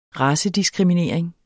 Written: racediskriminering

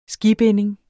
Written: skibinding

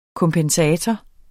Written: kompensator

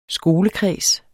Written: skolekreds

